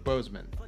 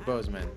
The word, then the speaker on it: Boseman: Chadwick Boseman. – Yeah. – Chadwick Boseman.